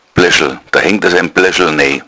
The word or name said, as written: bleschl